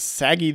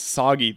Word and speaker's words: Saggy: And so Kaiba draws the crush card, and then he summons Saggy the Dark Clown, or they pronounce it in this one as Soggy the Dark Clown, still pretty gross.